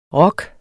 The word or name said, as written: rok